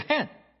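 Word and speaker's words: han (חן): 所以恩宠希伯来文中也有两个用词 hesed 和 han， hesed 更多是在人与神之间，但有时也会用于人与人之间，就像在创世纪 24章中亚伯拉罕的仆人的故事。就这里挪亚蒙恩，我来说说恩典这个词，恩典是 han，对吗？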